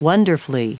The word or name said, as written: wonderfully